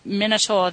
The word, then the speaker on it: Minotaur: It's the Greek Minotaur.